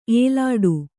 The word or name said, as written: ಏಲಾಡು